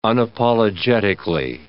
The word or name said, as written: unapologetically